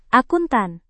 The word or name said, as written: akuntan